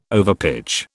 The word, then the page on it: overpitch: British English